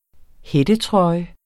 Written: hættetrøje

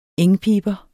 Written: engpiber

